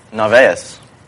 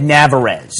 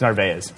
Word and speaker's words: Narvaez: I would have said Navarez. – That's what I thought it was. – That's what I was on the tip of my tongue was to say Navarez. But I was like, it's not Navarez. So I go, Ray, how the fuck do you pronounce your name? And he goes, Narvaez. And I was like, okay, it's Narvaez.